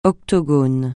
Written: octogone

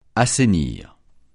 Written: assainir

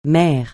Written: mer

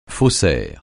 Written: faussaire

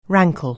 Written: rankle

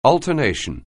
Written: alternation

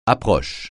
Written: approche

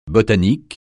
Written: botanique

